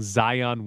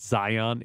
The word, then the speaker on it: Zion: There was a Festivus call specifically about me mispronouncing Zion Williamson's name, because it's not Zion, it's Zion. Like, I give a lot more emphasis on that last syllable.